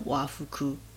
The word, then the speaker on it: 和服: 嗯，和服的话呢，用和服这两个字的话，它就念成哇夫库。